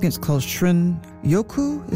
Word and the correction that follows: Shinrin-yoku: Is that how you pronounce it? The Japanese term for wood air bathing. – Shinrin-yoku.